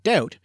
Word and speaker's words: doubt: This is when, for instance, the silent letter B is added to words like debt and doubt because it shows they came from the Latin words debitum and dubitare, even though they were never pronounced that way in English.